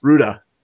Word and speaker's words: Hurda: is an interview with dungeon designer Robert Hurda. Hurda. I'm good a guess as any. – Yeah, Ruda. The H is silent.